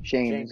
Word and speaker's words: Schoen: Was it Joe Schoen? Sean? Shane. – Shane. – Shane. Shane. Shane is the way to pronounce it.